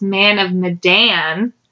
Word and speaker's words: Man of Medan: There was an interview done with the developers, and it's Man of Medan, because it's an actual place in Indonesia. – Okay, so it has Man of Medan.